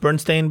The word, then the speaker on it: Berenstain: It's S-T-A-I-N. It's, which is Stain. – Stain. – And I know. – So is it pronounced Berenstain Bears? – Yeah, yeah, yeah.